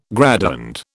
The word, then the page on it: graduand: British English